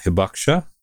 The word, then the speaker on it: hibakusha: Hibakusha, is that right? – No, that's good. Yeah.